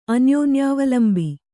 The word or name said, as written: ಅನ್ಯೋನ್ಯಾವಲಂಬಿ